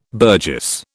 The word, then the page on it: burgess: British English